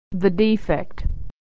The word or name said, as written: the defect